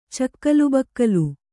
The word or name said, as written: ಚಕ್ಕಲುಬಕ್ಕಲು